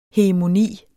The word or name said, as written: hegemoni